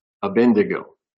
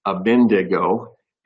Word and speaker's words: Abednego: And we started to look at the story of Shadrach, Meshach, and Abednego, which my wife told me I am pronouncing incorrectly. I'm saying Abednego, and my wife says that it should be Abednego. Abednego.